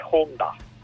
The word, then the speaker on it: Honda: e la cosa è Honda. Però senza accento, Honda. Però la cosa importante è aspirare l'H, mi hanno detto. Se aspiri l'H, sei uno di loro.